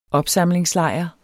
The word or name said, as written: opsamlingslejr